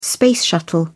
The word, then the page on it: space shuttle: British English